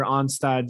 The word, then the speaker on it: Onstad: Pat Onstad. It's an A. – Is it? – Yes. O-N-S-T-A-D.